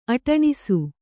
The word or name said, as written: ಅಟಣಿಸು